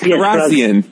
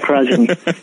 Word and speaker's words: Kharrazian: Yes, Kharrazian. That's right, Kharrazian.